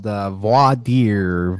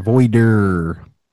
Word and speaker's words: voir dire: It's called voir dire, voider, ver dare, and every other mispronunciation I've heard today.